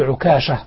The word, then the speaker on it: عكاشة: طبعا تعرف لما قام عكاشة بن المحصن، وجاز أن أقول عكاشة تنطق بالتخفيف وتنطق بالتسجيل مع الفاتحة، لما قال كذا طبعا النبي صلى الله عليه وسلم يعني قال أنت منهم الثاني، قال سبقك بها عكاشة.